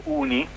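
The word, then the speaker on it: OONI: Senti, noi vorremmo sapere da te innanzitutto che cos'è ONI e come si pronuncia, UNI o ONI? – Va bene, sì, allora noi lo chiamiamo UNI in realtà, crea un po' di confusione con università, però diciamo questo è lo pronunciamo all'inglese.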